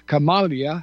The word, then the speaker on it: Kamala: And then, of course, this cackling nowhere witch woman, Kamala, I think is the way it's pronounced. – That's how you pronounce it.